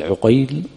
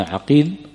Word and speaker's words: عقيل: النطق، كما قلنا عقيل وعقيل بفتح العين وبضم ماذا؟ العين.